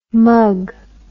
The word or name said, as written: mugg